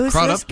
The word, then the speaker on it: Crudup: It's Crudup. Go ahead. – It's Crudup. – Oh, God. – It is. – Can't take Crudup. Not this week. It's Crudup. It's pronounced with a long U.